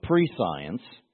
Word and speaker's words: prescience: And so pre-science, from the etymology of it a long time ago, pre-science, or what we call prescience, is the way you would pronounce it, is science, or knowing ahead of time.